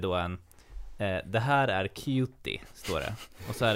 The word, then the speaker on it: Cutie: Cutie är engelska, uttalas Cutie. Alltså K-J-O-T-I. Cutie.